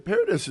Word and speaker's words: Paradis: Paradis is good now.